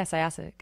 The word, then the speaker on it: Syosset: Syosset, New York? – Yeah. – Is that how you pronounce it? – Yeah, Syosset. Good job.